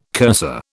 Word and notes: cursor: British English

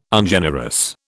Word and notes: Ungenerous: British English

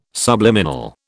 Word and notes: subliminal: British English

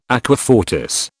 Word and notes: aqua fortis: British English

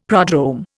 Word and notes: prodrome: Indian

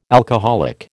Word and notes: alcoholic: American English

